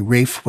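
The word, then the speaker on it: Ralph: And occasionally, at school or in the army, Rafe was called Williams, but that is not correct. Also, Rafe's name was pronounced R-A-F-E, and any other pronunciation used to infuriate him.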